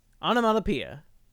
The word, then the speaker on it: onomatopoeia: You're not even saying it right. – Onomatopoeia. – I was trying. Mana. O N A. I don't know. I give up. Told you I can't spell it. – Okay. – Onomatopoeia. – It's onomatopoeia.